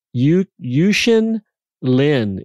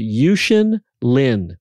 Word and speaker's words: Yuxin Lin: His name is Yuxin Lin. Yuxin Lin is how you pronounce it. Yuxin Lin.